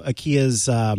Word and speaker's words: IKEA: Let's move on to IKEA's, which, by the way, forgive me because I listened to a Swedish commercial about the brand IKEA, and in that commercial, the voiceover pronounced it as IKEA and not IKEA. And so in my head and sometimes out loud, I say IKEA.